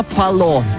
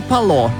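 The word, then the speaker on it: Pallot: Почти как полет, но Пало. Нерина. На самом деле, не удалось, правда, узнать, откуда такое странное имя и еще более странная фамилия. Написано, пишется Палот, но на диске указано, что последнее «Т» не читается.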